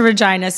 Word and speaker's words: Regina: I've never been to Regina, so I have no idea. – No, well, like Deadpool says, it's a city that rhymes with fun.